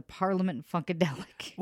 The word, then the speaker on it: Parliament Funkadelic: Parliament Funka. I can never say it right. George Clinton and the Parliament Funkadelic.